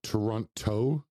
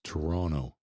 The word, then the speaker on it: Toronto: Don't pronounce the second T. It's not Toronto. It's Toronto.